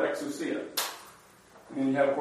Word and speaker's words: exousia: Exousia. Exousia. Okay? The stress is on the C. Accent.